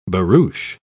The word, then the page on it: barouche: British English